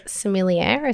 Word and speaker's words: sommelier: Well, Kate's a sommelier, is that how you say it? – Ah, yes, yeah.